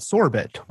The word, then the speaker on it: Sorbet: We pronounce it sorbet. Maybe I'm Canadian originally, so my French accent can drive a little bit more in there.